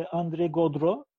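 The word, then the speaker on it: André Gaudreault: Evet yani e, bu e, kinematik dönemecin yazarlarından özellikle André Godreau e, öyle okunuyor Fransızca'da bu arada. Ben hep Godreau falan diyordum. Godreau diye okunduğunu öğrendim.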